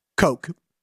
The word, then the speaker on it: Koch: His last name, spelled K-O-C-H, pronounced Coke.